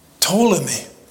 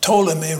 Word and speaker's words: Ptolemy: Ptolemy, again the P is silent, Ptolemy ruled over Egypt.